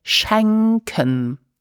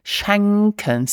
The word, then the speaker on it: schenken: And then to give presents, you will use the verb schenken. Let's repeat, schenken. So you need to open the mouth for the correct pronunciation. Schenken.